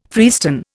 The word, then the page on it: freestone: Indian